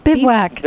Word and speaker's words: bivouac: Bivouac. – Bivouacked. – Didn't you know anyone in the, what is the art of I've been to a bivouac. Okay, I just didn't know how to pronounce it.